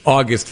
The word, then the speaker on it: August: August Financial Planning. And thanks for having me, John. – As in the month, not as in the exaltation.